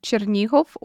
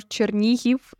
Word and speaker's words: Chernihiv: Chernihov or Chernihiv in more like Ukrainian pronunciation.